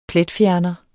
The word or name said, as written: pletfjerner